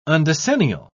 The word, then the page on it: undecennial: British English